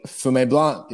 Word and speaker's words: Fumé Blanc: How was the Fumé Blanc? Did I say that right? – Fumé Blanc. You better get it right, bro.